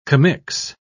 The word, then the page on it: commix: British English